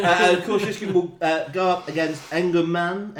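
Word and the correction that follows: Energumene: Engermain. – Um, Energamine is often how it's pronounced.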